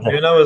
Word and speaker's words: Nuno: Nunes, is it? – Nuno, isn't it? – Nuno, yeah. So he's had to come in and deal with this from Kane.